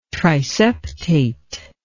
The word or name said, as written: triseptate